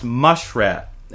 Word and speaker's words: muskrat: he trapped a muskrat, killed it, skinned it, and got a whopping 75 cents for the pelt. Actually, my father tells me that in Downriver, it's pronounced mushrat,